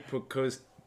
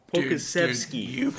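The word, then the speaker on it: Pokuševski: The Knicks, I went with the Serbian guy, Alexei Pokosky. Pokusevski.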